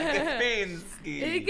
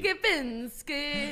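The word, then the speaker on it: Képinski: Est-ce que c'est Lydia Kepinski? C'est comme ça qu'on prononce? – Lydia Kepinski. – Kepinski.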